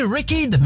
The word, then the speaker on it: Wrecked: Il ne faut pas prononcer wrecked, mais wrecked. Mais c'est pour le bien de l'éducation de nos enfants d'écouter la bonne prononciation.